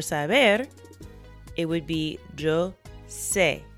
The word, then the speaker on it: sé: And it really doesn't change the pronunciation in this course.